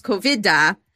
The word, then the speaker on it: Kovid: But did you know that there is a name in Sanskrit, which is COVID, or as it's pronounced, COVIDA, but it's spelled K-O-V-I-D.